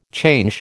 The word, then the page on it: change: American English